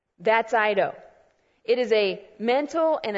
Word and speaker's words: oida: And that other Greek word is the word eido. It's spelled O-I-D-A if you're one that likes to write it down. O-I-D-A, eido. And here's what eido means.